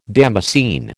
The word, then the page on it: damascene: American English